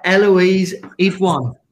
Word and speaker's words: Eloise Idoine: We've got Eloise Idoine.